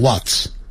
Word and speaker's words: What's: 听好 What's 就等于 What is 的缩写 IS 连在一起，我们就把 I 去掉，改成一撇 What 加一撇加个 S，念的时候念成 What's，在英式英语会念成 What What's，可是在美式的时候它那个 H 的音它就不发出来，只是念成 What 加一撇加 S 念成 What's，就是 What is 的缩写。